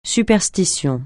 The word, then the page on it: superstition: francais